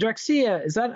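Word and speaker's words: Drexciya: And Drexia, am I saying that right? Any idea? – Yeah, no, that's right.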